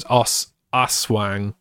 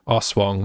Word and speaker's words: Aswang: I don't think they said Wang. I think that's me mispronouncing it. I think it was Aswang. – Aswang. – Aswang. That sounds better.